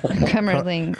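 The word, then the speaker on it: Kümmerling: It's Kummerling. – Kummerling? – Kummerling? Is that it? – Spell it. – K-U-E-M-M-E-R. – Sounds dirty. – L-I-N-G. – Mm. Like cum and cumlingus put together. – Kummerling.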